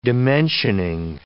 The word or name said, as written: dimensioning